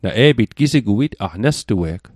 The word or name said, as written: nestue'g